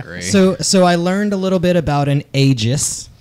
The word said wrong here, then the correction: aegis: Sorry, it's Aegis. – Aegis. Okay. – Yes. The E has the long E little thing over it.